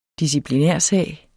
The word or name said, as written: disciplinærsag